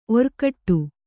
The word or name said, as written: ಒರ್ಗೊಡೆ